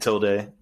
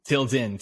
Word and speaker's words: Tilden: You're a resident DJ at a certain place at Tilde. Did I say it right? Shout out Tilde. – Tilde. Tilden, T-I-L-D-E-N. – All right, Tilden.